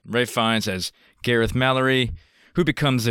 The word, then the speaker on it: Ralph Fiennes: Everybody gets it wrong. It's Ralph Fiennes. It's spelled Ralph, but his name is Ralph Fiennes.